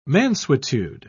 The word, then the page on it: mansuetude: British English